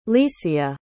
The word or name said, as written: licia